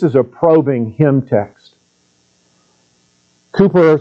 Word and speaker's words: Cowper: Do you know this hymn tune by William Cowper is the way you pronounce his name. It looks like Cowper.